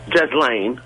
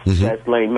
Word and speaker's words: Ghislaine: I just had, I'm a bit curious about, you're talking about Jeffrey Epstein, and Jasmine Maxwell, Ghislaine, I think her name is pronounced, Ghislaine Maxwell, has been languishing in jail for a crime that wasn't committed, or wasn't committed.